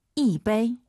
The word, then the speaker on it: 一杯: 一杯. – 오, 청취자분들 지금 이杯 일성으로 하시는 분 계시네요. 숫자 1 다음에 일성이 오면은 숫자 1이 사성으로 바뀝니다. 이杯 이렇게 발음하세요.